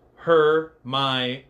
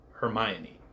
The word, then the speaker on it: Hermione: But we finally, and this is the fabled line that told everybody how to actually pronounce her name. Hermione. Hermione.